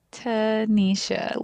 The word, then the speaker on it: Tanisha: my name is Tanisha, and people are like, what? Like, how do I say that? And I'm like, Tanisha.